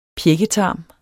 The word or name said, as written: pjækketarm